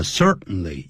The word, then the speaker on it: certainly: 他说我们的确肯定的 definitely、就是确然的，也就是 certainly, C-E-R-T-A-I-N-L-Y。这个时候不要念成 certainly,通常念快的时候叫 certainly, 用 n 的音，用鼻音。We all certainly need,